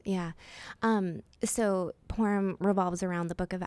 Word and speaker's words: Purim: So just recently, I think it was Purim. Is that Purim. – How you say it? – Mm-hmm.